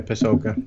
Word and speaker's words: Pasulka: Yeah, Diana Pasulka.